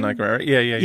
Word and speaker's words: Danai Gurira: Is that how you pronounce her name? – Danai Gurira. Yeah.